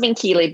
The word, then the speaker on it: Minchilli: If you just Google my name, Elizabeth Minchilli, it's pronounced Minchilli, but it's spelled Minchilli, M-I-N-C-H-I-L-L-I.